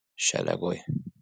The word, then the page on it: Şələqoy: ʃæɫæˈɡoj; Azerbaijani